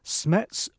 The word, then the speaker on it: SMETS: Also, he goes on to say, I'm interested in what you can tell me about SMETS 1 versus SMETS 2 meters. Am I saying that right, Stephen? – I think you are, yes.